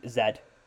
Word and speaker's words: Stutz: That's a documentary on Netflix by Jonah Hill, and it's Stutz.